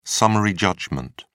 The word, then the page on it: summary judgment: British English